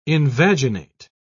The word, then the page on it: invaginate: British English